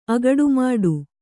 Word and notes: ಅಗಡುಮಾಡು: agaḍu māḍu; agaḍumāḍu

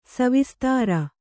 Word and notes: ಸವಿಸ್ತಾರ: kannada; savistāra

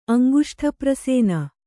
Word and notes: ಅಂಗುಷ್ಠಪ್ರಸೇನ: kannada

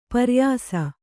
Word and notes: ಪ್ರಾಯಶಃ: prāyaśah

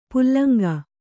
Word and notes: ಪುಲ್ಲಂಗ: pullaŋga

